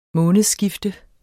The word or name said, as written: månedsskifte